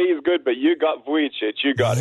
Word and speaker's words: Vujicic: How do you say it? – Vujicic. – Vujicic. Well, that's not the way it's spelled on the little thing I have here. It says Cheech.